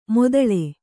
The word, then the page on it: ಮೊದಳೆ: modaḷe